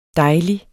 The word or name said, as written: dejlig